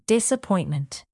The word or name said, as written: disappointment